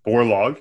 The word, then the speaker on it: Borlaug: We were talking about an interesting character right before we fired up the engine, Mr. Norman Borlaug, if I'm saying it correctly.